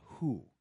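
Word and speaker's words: Hu: That's the Who, though it's spelled H-U. But in Mongolia, they call that Who.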